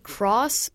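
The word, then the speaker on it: key (χ): There's Key or is Kingdom Hearts Key? It's like a lower X thing. They pronounce it cross, but also it's supposed to be pronounced key in the context of the game.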